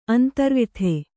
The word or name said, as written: ಅಂತರ್ವ್ಯಥೆ